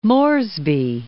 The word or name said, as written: Port Moresby